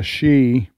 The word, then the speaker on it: Xi: But one is President Trump is meeting with the leader of China, Xi. We keep saying Xi, it's X-I. Mm-hmm. That's the way you pronounce it.